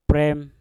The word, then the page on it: prem: mpur